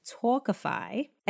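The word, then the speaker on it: Tawkify: That's T-A-W-K-I-F-Y. So really it's pronounced Talkify.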